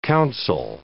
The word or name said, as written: counsel